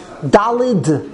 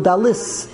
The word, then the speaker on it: Dalet: How do you say? Dalid. Dalid with a D, right? Dalid. But here it's Dalis. Dalit. Dalit. Dalis.